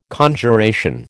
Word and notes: Conjuration: American English

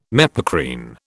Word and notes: mepacrine: British English

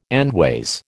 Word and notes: endways: American English

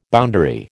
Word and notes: boundary: American English

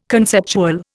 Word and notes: conceptual: Indian

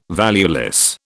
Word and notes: valueless: British English

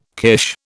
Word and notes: kirsch: British English

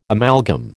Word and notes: amalgam: American English

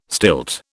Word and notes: stilt: British English

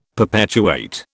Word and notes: perpetuate: British English

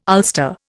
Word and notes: ulster: Indian

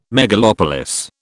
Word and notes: megalopolis: British English

